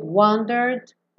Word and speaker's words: wandered: But we've wandered. Também não vai pronunciar o último E. Então, do R, wandered. Já vai pro D.